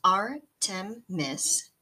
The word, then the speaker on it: Artemis: Pronounced Artemis, A-R-T-E-M-I-S, the Greek goddess Artemis is the twin sister of Apollo, the sun god.